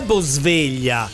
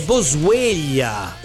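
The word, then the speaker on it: Boswellia: Non è Bosveglia, è Bosveglia. – E cosa cambia? – Che si scrive diverso. Io pensavo alla sveglia, invece no, è Bo attaccato SWELLIA.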